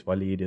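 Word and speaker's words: W'leed: But actually, in this case, Waleed is, it's got that sort of dropped syllable in the original Arabic, so the apostrophe is actually usually how you indicate that.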